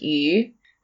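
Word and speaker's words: yu (鱼): People will eat fish, which is pronounced yu, because it's got the same pronunciation as surplus, which is yu, and you want to have a bountiful year.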